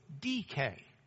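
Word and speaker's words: DK (δίκη): Dike is the D, the delta, the iota, the kappa, and the eta, which is a long E sound, or A is the way we do it. But it's emphasized on the first syllable, so it's DK.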